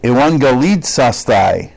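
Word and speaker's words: euangelizasthai: It's euangelizaste. Did I say that right? Euangelizastai. Probably better.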